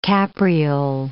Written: capriole

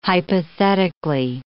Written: hypothetically

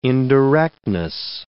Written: indirectness

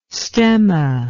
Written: stemma

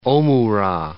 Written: omura

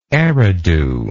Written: eridu

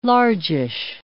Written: largish

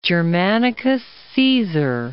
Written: Germanicus Caesar

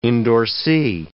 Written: endorsee

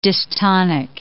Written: dystonic

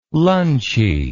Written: lunchy

